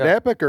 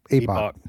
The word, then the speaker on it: Epoch: Is it Epoch or Epoch? – Epoch. – Oh, I got that one right.